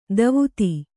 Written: ಧೋವತಿ

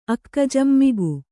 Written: ಅಕ್ಕಜಂ ಮಿಗು

